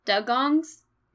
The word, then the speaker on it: dugong: dugongs. And the oh, the dugong. – Dugong, thanks. It's spelt like D-U-G, so it looks like duh, like duh, yeah, dug. Dugons, that's right.